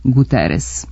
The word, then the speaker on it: Guterres: Tu S uvek ostaje S, pa Guterres u Portugaliji može da bude Guterres, ali kod nas on je Guterres.